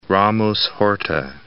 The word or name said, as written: Ramos-Horta